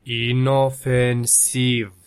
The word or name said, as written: inofensiv